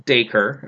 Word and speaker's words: Dacre: And First name pronounced Dacre, as according to IMDb.